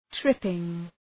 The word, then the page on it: tripping: ‘trıpıŋ